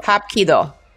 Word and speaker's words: Hapkido: Hap. Wait, hop, and then ki, do. Hop ki do. – Yes. – Oh, that's Korean.